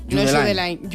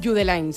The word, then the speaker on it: Judeline: Pues entonces Judeline. Judeline. Si es de Barbate, Judeline.